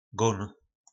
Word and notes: gon: po nasimu

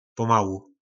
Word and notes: pomalu: po nasimu

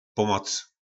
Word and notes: pomoc: po nasimu

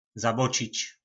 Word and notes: Zabocic: po našimu

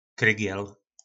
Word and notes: krygel: po našimu

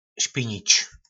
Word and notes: spinic: po nasimu

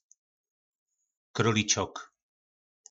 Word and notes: krolicok: po našimu

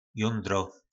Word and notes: jondro: po našimu

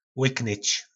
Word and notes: lyknyc: po našimu

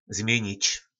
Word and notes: Zmjynic: po našimu

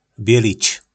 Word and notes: Bjylic: po nasimu

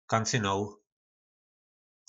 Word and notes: Kancynol: po našimu

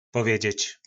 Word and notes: povedzec: po nasimu

